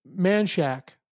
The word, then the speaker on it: Manchaca: I mean, it's so close, but like you talk about things like there's a town south of Austin called Manchac, but it's spelled Manchaca, Manchaca. But people say Manchac.